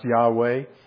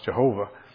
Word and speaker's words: Yahweh: We think it's pronounced Yahweh, but used to be pronounced Jehovah.